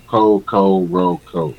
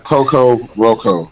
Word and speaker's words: Kokoroko: Coco Roco. K-O-K-O-R-O-K-O. Coco Roco. – It's only three letters in this whole name. So, yeah. And it's K's, right. K's, O's, and R's. – It's spelled like it, how it sounds, exactly. Honestly. – There's no C's.